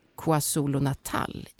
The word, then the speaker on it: KwaZulu-Natal: University of KwaZulu-Natal i Sydafrika. Uttalade jag det där rätt, Jesper? – Det var alldeles strålande.